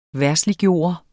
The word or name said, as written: verdsliggøre